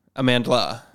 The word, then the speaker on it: Amandla: And Amanda Stenberg. – Yes. – Is that right? How do you say it? Stenberg, yeah. And Amandla.